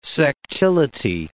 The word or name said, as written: sectility